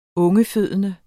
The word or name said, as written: ungefødende